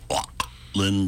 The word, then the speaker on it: Auckland: Then finally, last night in Auckland, New Zealand. That's the only way to pronounce that. Sounds like a noise you'd make if you were awking something up.